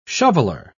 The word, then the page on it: shoveler: British English